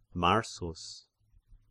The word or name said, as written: Marsus